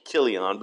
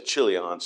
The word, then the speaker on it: Chilion: And the CH there is also like a K. It's almost Kilion, but I really just say Chilion.